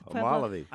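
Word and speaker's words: Homolovi: Homolivi. – I know you pronounced it right, but I haven't been there. I don't know where it is, but I know that's the right pronunciation.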